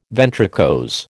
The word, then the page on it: ventricose: American English